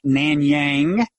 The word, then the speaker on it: Nanyang: You teach philosophy at Nanyang, which you've told me is a satisfactory pronunciation.